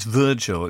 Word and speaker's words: Virgil: actually it's Virgil, it's pronounced the English way.